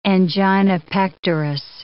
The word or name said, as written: angina pectoris